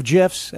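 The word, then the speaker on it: GIF: GIFs, and it is a soft G, a cavalcade of them.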